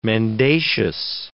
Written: mendacious

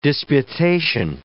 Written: disputation

